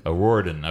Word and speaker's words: O'Riordan: Um, and one more lady, and I have no idea uh, how to pronounce her last name, but it's Dolores O'Riordan. O'Riordan.